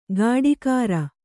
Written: ಕಾಡೀಖಾರ

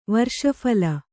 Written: ವರ್ಷಫಲ

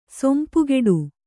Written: ಸೊಂಪುಗೆಡು

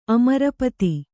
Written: ಅಮರಪತಿ

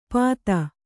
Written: ಪಾತ

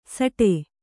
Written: ಸಾಟೆ

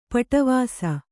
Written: ಪಡಿವೆಸ

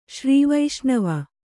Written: ಶ್ರೀವೈಷ್ಣವ

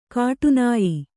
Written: ಕಾಠಿನ್ಯ

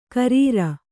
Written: ಖರಾರಿ

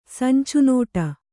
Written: ಸಂಚುನೋಟ

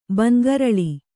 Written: ಬಂಗರಳಿ

